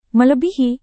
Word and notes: melebihi: Indonesian

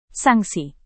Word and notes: sanksi: indonesian

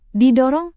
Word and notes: didorong: Indonesian

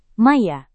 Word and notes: maya: Indonesian

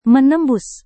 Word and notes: menembus: Indonesian

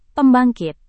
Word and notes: pembangkit: Indonesian

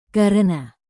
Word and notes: karena: Indonesian

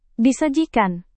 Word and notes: disajikan: indonesian

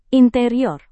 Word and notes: interior: indonesian

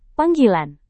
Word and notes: panggilan: Indonesian

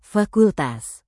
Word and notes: fakultas: indonesian

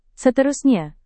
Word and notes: seterusnya: indonesian

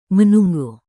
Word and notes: menunggu: Indonesian

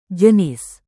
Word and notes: jenis: Indonesian

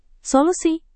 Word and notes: solusi: Indonesian

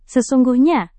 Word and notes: sesungguhnya: Indonesian

